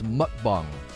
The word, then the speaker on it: mukbang: It's pronounced mukbang.